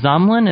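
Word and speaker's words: Zamlen: And this is sort of the early beginnings of Zamlin. Am I saying that right? – Yes.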